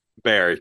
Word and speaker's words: Beer: But Paula Beer is pronounced Bear, not Beer. – Bear, correct.